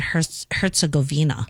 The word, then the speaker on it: Herzegovina: Well, I like to use Google to pronounce things for me. And it said Herzegovina. – Oh, it did. Okay, so I was saying it correctly.